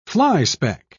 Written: flyspeck

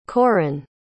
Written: coryn

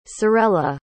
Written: cerella

